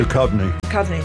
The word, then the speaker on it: Duchovny: And on Jared's Culture Show, starting at 2, it's a best-of episode featuring David Duchovny. I think I said that right. – Duchovny. – Thank you. Thank you.